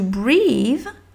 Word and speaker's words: breath: Quand tu vas prononcer breath, breath, tu vas écrire breath comme euh, euh, la bre bread par exemple, enfin ou bread, breh, breh, donc b r e accent circonflexe comme crêpe, une crêpe,